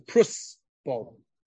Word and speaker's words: Prosbul: It's actually, if you look at the Lashon of the Gemara, it's not a Prusbul. It's a Prusbul. Everyone pronounces it wrong. It's not a Zayin. It's really a Samach.